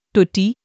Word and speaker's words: Tucci: With 78,840 views on Friday, the 1st of May 2026, our article of the day is Stanley Tucci. Stanley Tucci Jr., Tucci Italian pronunciation, Tutti, born November 11, 1960, is an American actor.